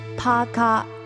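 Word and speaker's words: paka (パーカー): Okay, let's start by explaining the word parker, which we pronounce paka in Japanese.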